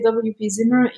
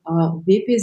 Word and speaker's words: WP Zimmer: How do you actually say the organization name? – In English, we would say WP Zimmer. In Flemish, you would say VEPE Zimmer, which is Werkplatz, workspace.